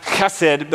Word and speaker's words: hesed: It's hesed, H-E-S-E-D. I think in Hebrew it's technically pronounced chesed, but I'm not going to try to make that sound the entire time, because that'll be weird for all of us.